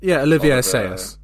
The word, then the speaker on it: Olivier Assayas: Olivier Assayas.